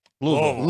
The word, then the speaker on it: Louisville: Louisville. – Louisville. – It's like Louisville. – One syllable.